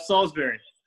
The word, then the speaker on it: Salisbury: Did I say this right? – Close enough, Salisbury.